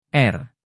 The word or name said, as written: r